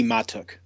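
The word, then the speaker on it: Mahtook: The pronunciation apparently is Mikey Matuk. – Matuk. – Matuk. – Emphasis on the first one. That's according to Baseball Reference.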